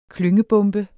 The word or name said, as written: klyngebombe